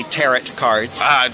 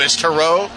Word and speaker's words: tarot: Well, you know, people from the Midwest say tarot cards. – Like carrot, but yeah. – Yes. – But it's tarot? – Well, from the French.